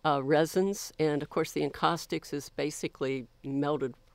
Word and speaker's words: encaustics: So about your art, I saw that you were interested in the medium of, sorry, I don't know really how to say this, encaustics? Is that encaustics? – Correct? – Encaustics.